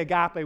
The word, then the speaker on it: agape: Now, some people call it agape, but I looked it up, or I actually talked with a friend of mine, and it is agape love, all right? So you can say agape with confidence, that's how you say it.